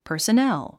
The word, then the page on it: personnel: pə̀ː r s ə nél; パァセネェゥ